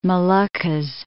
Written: Moluccas

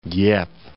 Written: Dieppe

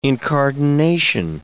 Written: incardination

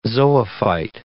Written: zoophyte

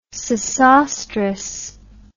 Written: Sesostris

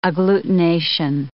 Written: agglutination

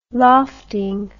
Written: lofting

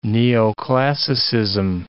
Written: neoclassicism

